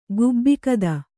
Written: ಗುಬ್ಬಿಕದ

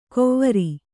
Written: ಕೋವರಿ

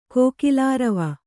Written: ಕೋಕಿಲಾರವ

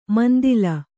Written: ಮಂದೀಲ

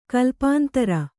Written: ಕಲ್ಪಾಂತರ

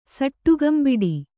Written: ಸಟ್ಟುಗಂಬಿಡಿ

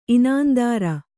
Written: ಇನಾಂದಾರ

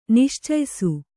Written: ನಿಶ್ಚಯಿಸು

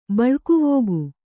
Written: ಬಳಗವಿಕ್ಕು

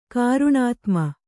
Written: ಕಾರುಣಾತ್ಮ

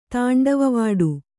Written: ತಾಂಡವವಾಡು

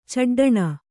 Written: ಚಡ್ಡಣೆ

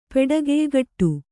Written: ಪೆಡಗೆಯ್ಗಟ್ಟು

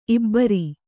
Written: ಇಬ್ಬರ್

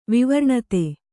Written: ವಿವರ್ಣತೆ

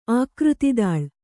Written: ಆಕೃತಿದಾಳ್

